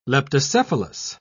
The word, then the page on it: leptocephalus: British English